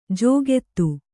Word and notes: ಜೋಗೆತ್ತು: jōgettu

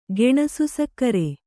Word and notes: ಗೆಣಸುಸಕ್ಕರೆ: Kannada

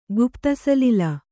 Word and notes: ಗುಪ್ತಸಲಿಲ: Kannada

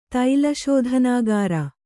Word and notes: ತೈಲಶೋಧನಾಗಾರ: kannada

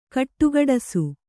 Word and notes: ಘುಡುಘುಡಿಸು: ghuḍughuḍisu

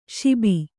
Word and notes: ಶುಭ: śubha

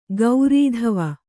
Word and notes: ಗೌರೀಧವ: kannada

